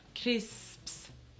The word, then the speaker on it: crisps: Sí, el hecho de que haya tres consonantes al final nos cuesta mucho. Entonces ya se dice crisps.